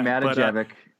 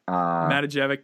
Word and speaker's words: Matijevic: I want to say it's JJ Matijevic. I could be very wrong. – JJ Matijevic. – Matijevic.